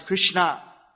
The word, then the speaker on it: Krishna: So that should, you should try to pronounce as Krishna. There may be some people that cannot, I think you could pronounce S. So Hare Krishna should be clearly pronounced.